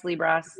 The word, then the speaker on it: Liebross: Yep, Liebross. You know, whatever you want to say. Liebross. Right. I always like to say it rhymes with gross.